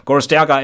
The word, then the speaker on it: Gorostiaga: Gorostiaga et al.